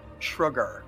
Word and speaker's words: Shrugar: And how do you pronounce that name again? – Shrugar. So kind of like shrugging.